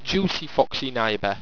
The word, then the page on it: jucifoxyniba: Owstwecian